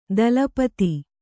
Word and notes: ದಲಪತಿ: dala pati

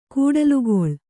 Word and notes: ಕೂಡಲುಗೊಳ್: kūḍalugoḷ

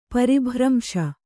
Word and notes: ಪರಿಭ್ರಂಶ: pari bhramśa

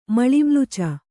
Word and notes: ಮಲಿಮ್ಲುಚ: malimluca

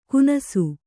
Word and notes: ಕುನಸು: Kannada

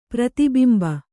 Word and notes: ಪ್ರತಿಬಿಂಬ: prati bimba

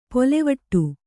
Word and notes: ಪೊಲೆವಟ್ಟು: polevaṭṭu

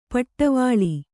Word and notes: ಪಡೆವಳ: paḍevaḷa

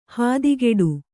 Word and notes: ಹಾದಿಗೆಡು: hādigeḍu

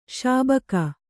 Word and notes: ಶೌಭಿಕ: śaubhika

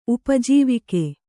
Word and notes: ಉಪಜೀವಕ: upa jīvaka

